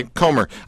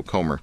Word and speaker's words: Comer: Is it Comer? How do you say your last name? – Comer. – Okay, Comer. I knew I was mispronouncing it, and I didn't want to keep going through the bio and slaughtering your name, right? So, Comer.